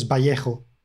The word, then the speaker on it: Vallejo: Well, we would pronounce it as Vallejo. – Oh, bye. Well, thank you. I don't know what the correct pronunciation is. – I mean, me neither, because I don't know the origin of the surname, but in Spain, he is Boris Vallejo.